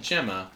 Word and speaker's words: Gemma: Our saint is Saint Gemma, actually pronounced Saint Gemma.